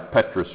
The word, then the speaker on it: petrous: Yeah, petrous region. Sometimes words that start with a P, you don't pronounce the P, but in that word you do. Okay?